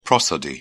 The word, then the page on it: prosody: British English